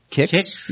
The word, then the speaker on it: Qix: I think it's pronounced the Kix. Yeah, it's like QIX.